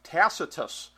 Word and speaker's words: Tacitus: Tacitus is what I'm saying. That's my phonetic reading of it anyway. So am I correct, gentlemen? – Yes. – Okay. – Yes. – Lucky guess. All right. Tacitus with trainer Bill Mott.